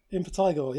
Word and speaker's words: Impetigore: My high is going to be, I'm probably going to pronounce it wrong, Impetigore. – Impetigore. – Is that how you say it? – Impetigore, yeah.